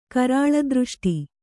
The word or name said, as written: ಕರಾಳದೃಷ್ಟಿ